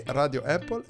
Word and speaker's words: Radio Apple: Allora, come sempre, uh, marchettone finale at Radio Apple su Twitter, così come lo pronunciamo con la E, Radio Apple.